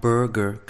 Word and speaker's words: Berger: Jag rekommenderar John Bergers böcker. Eller John Berger kanske du uttalas. Eller Berger, för han har flytt till Frankrike.